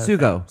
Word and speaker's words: sugo: I had suego, which is... – Is it pronounced suego? – Yeah. No sugo, thanks. Sugo. It was pig cheek ragu.